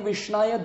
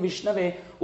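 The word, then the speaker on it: viṣṇave: Murkho vadati vishnaya dhiro vadati vishnave ubhayostu samam punyam bhavagrahi janardhana. According to Sanskrit, it's vishnave. But people might say vishnaya, which is according to grammar, it's wrong.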